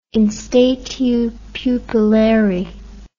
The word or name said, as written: in statu pupillari